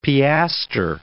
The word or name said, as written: piastre